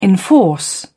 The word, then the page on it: enforce: British English